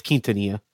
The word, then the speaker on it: Quintanilla: It's Quintanilla.